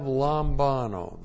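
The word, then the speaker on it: Lambano (λαμβάνω): And then we have lambano, the verb lambano, which looks like this in the Greek. L-A-M-B-A-N, long O, Lambano.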